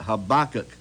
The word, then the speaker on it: Habakkuk: Verse 1 of chapter 3, a prayer of Habakkuk, that's how you pronounce it, Habakkuk, the prophet from Shigenoth.